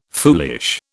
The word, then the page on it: foolish: British English